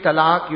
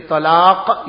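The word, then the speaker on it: talaq (طلاق): He says, you know when you gave talaq, you didn't pronounce the qaf. You said with a kaf. So you didn't say talaq, you said talaq.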